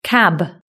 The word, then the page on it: cab: British English